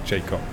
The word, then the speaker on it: Jakob: Jakob in German. – And in English, you would pronounce it Jacob.